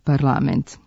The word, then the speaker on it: parlament: Pravila naglasak je parlament, jer akcenti u srpskom jeziku ne mogu da stoje na poslednjem slogu u reči.